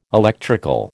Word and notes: electrical: American English

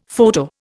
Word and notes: photo: Indian

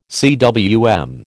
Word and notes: cwm: American English

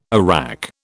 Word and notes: arrack: British English

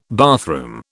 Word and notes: bathroom: British English

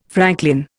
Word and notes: franklin: Indian

